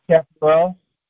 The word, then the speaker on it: chaparral: But what they don't know is burdock, chaparral, how you spell that? How you pronounce that? Chaparral? – Chaparral. – Chaparral, yeah, chaparral. Elderberries or elder flowers, garlic, goldenrod, ground ivy, juniper, lobelia, marshmallow, Mormon tea, also known as ephedra.